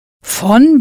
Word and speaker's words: von: For of, in this sentence, you'll use von, V-O-N, pronounced von.